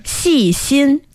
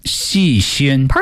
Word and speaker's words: 细心: How do you say that one again? – 细心. – 细心. – Perfect.